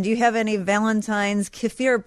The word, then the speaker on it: kefir: In Russian, we say kefir. – Kefir.